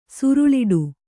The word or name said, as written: ಸೂರಳಿಡು